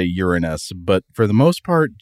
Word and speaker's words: Uranus: If you listen to astronomers and planetary scientists talk about this planet, most often you will hear them pronounce it Uranus, basically the word urine and then the word us.